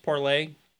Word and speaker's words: Parler: So like I said, it was originally supposed to be pronounced Parlay. I don't know why they spelled it the way they did. I guess if you're trying to spell it, the French spelling for parlay has an R in it. So to register a domain, you can't put the funny accents in whatever. So it ended up Parler. So here we are. Everyone pronounces it Parler.